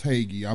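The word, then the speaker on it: Hagee: It's John Haggai. It's pronounced Haggai.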